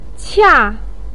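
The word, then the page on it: qia4: tone 4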